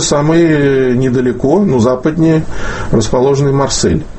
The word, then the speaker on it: Марсель: Ведь мы по-французски произносим «Марсей». Да, и мы должны были бы писать с «и» кратким. У нас есть буква даже, так сказать, которая очень близко передает звучание. Нам нужно было бы писать «Марсей».